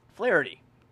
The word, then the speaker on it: Flaherty: Flaherty.